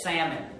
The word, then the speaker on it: Salmon: Most people, this is not like the fish, salmon, this name. It's pronounced Salmon. That's how you pronounce that name.